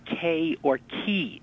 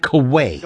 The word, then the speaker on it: quay: The correct pronunciation of the word of the night is K or key.